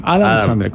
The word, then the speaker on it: Adam Sandler: Adam Sandler. Como los chicles Adams, pero sin la S.